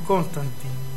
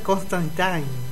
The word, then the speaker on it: Constantine: Nosotros le decíamos Constantine. – Claro. – Y ahora es Constantine.